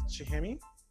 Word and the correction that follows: Cuchame: That's the theater company from Argentina, right? I don't know if I said it right. I think I pushed it. – Yeah. That is Cuchame. – Cuchame, which I looked up, which means spoon.